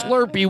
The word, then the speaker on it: Slurpee: I'm saying it wrong. I can't put this on Philadelphia. They're going to kill me. It's Slurpee.